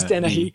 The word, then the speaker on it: Dennehy: would you say Dennehy? – Yeah, yeah. – Okay. It is Dennehy, yeah. Is it? Okay. – I mean, I would say, like, that is the American pronunciation, at least.